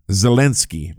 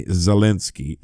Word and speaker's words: Zelensky: Zelensky, by the way, Zelensky. It really irritates Ukrainians to hear you mispronounce their president's name. And I just did it.